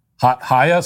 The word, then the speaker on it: Chaya: It's pronounced Chaya, but whatever works. – Chaya? So is Chaya fine? – So it's from the throat.